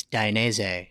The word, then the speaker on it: Dainese: It's Dainese.